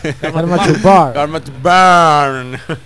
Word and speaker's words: Karma to Burn: Karma to Burn.